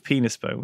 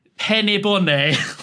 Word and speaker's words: Penisboné: You think it's a coincidence that someone by the name of Penisbone would end up directing the Penis Museum? – It's probably not pronounced like that. It's more like Penny Bonnet.